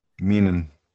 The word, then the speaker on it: Meenan: I hope I'm saying your last name. M-E-N-E-E-N. Menon or Meenan? – Meenan. – Meenan. Am I saying it right, Donnie?